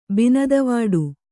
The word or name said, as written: ಬಿನದವಾಡು